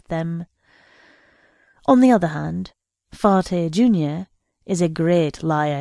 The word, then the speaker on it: Farte: He pronounces his name Farte with a great French stress on the last syllable.